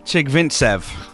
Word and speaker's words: Chigvintsev: Chigvintsev.